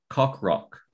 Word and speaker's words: Coqroq: Cockrock. C-O-Q-R-O-Q.